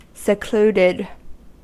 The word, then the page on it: secluded: American English